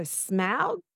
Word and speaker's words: Smaug: Apparently you say it like cow, so Smaug, but Smaug, Smaug.